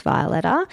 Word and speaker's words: Violeta: So it's the color violet with an A at the end. It's just got one T in it. Jeez, it requires a lot of explanation. It's our surname. We're not pronouncing it correctly, but we're just rolling with how we're saying it now, which is Violetta.